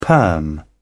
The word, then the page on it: perm: British English